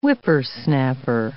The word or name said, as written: whippersnapper